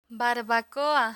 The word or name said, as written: barbacoa